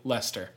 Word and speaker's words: Leicester: speaking of cities, a hard-to-pronounce city that we are pronouncing correctly, Leicester. Thank you very much.